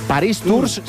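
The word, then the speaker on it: Paris-Tours: Por cierto, la prueba élite de esta Paris Tour, Paris Tours se escribe, Paris Tour se pronuncia, Alexis.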